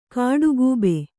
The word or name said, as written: ಕಾಡುಗೂಬೆ